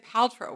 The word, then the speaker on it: Paltrow: Like, on the one hand, there's me, and on the other hand, there's Gwyneth, the Spence School, East Side of New York, Paltrow, which, by the way, you said Paltrow, which I feel is like a real, you know, she's your pal. – That's actually how she pronounces her last name.